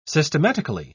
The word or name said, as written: systematically